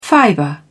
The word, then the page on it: fiber: British English